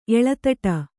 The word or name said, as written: ಎಳೆದಾಡು